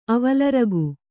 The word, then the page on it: ಅವಲರಗು: kannada